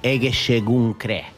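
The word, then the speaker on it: Egészségünkre: Actually, we're going to say Egeshegunkre.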